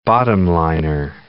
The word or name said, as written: bottom-liner